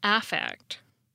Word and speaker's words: affect: And notice that I pronounce the word differently. When it's a noun this way, it's pronounced affect.